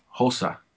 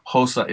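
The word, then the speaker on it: Xhosa: Hosa. So it sounds like Hosa, Hosa, but I think it's spelled Xhosha. I think I'm probably sure I'm saying that wrong, but it's Hosa is the language.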